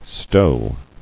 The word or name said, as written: Stowing